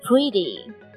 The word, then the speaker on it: pretty: 同时注意这个单词 pretty 是长元音的 e pretty。